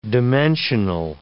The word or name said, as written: dimensional